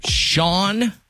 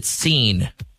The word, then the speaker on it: Sean: Mainly, is it Sean or is it Seen? – It's never Seen, but it is spelled S-E-A-N.